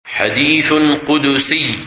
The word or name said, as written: Hadith Qudsi